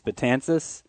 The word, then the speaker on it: Betances: How to say his name? It's Betances. – I They always say Betances on the broadcast. It's probably, you go to his house, they're going to call him Betances.